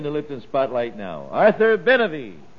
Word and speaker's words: Benavie: Arthur Benavie. 20-year-old violinist. – Oh, grand. Now, give me his last name again, please. – Benavie. – Benavie. How do you spell it? – B-E-N-A-V-I-E.